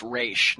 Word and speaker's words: Ra's: it's pronounced Ra's, not yeah. Raz or Oz.